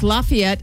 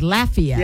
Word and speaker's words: Lafayette: In Indiana, it's Lafayette, Indiana. But down in Louisiana, it's Lafayette.